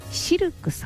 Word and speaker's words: シルク: そして南青山にございますフレンチレストランシルクさん、 CILQ と書いてシルクさん、